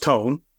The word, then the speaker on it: Toon: Well, Joop Vermolen co-created and starred in the Netflix comedy Tone, and that's spelled T-O-O-N, like cartoon, but it's pronounced Tone.